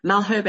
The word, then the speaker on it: Malherbe: Dominique Malherbe, excuse my shocking pronunciation.